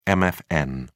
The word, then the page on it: mfn: British English